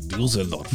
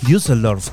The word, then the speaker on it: Düsseldorf: de Düsseldorf. – Düsseldorf, qué tiempos aquellos en los que decíamos Düsseldorf.